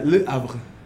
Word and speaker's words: Le Havre: Le Havre. – Le Havre.